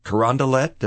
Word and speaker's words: Carondelet: Today, we're going to a place called the Brazzacat Brazilian Steak and Seafood House on Carondelet, did I say that right? – Yeah.